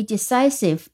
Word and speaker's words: decisive: 好，那么 decision 其实还有一个名词形式叫做 decisive，decisive 哦 decision，然后这个把 shin 变成 sif，但是呢，这个 si 就要变成 si 了，所以它读音是 decisive，decisive 第二个音节是重音 d e c i 读成 si，sif，s i v e。 decisive decisive 是果断的意思。我们来看一个例句：A good leader needs to be decisive in making choices。